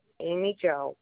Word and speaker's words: Amy Jo: A is in apple, M is in Mary, Y is in yak, J is in Jack, O is in octopus, Amy Jo,